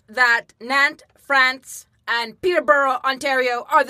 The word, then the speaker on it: Nantes: Now, you have also spent time in Nantes, France.